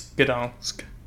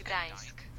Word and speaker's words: Gdańsk: I think it's Gdansk. – The G is is it's a hard G.